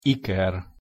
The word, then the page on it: iker: Hungarian